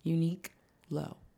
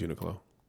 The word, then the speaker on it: Uniqlo: How are you pronouncing it? – Unique low. – Mm, I think it's Uniqlo.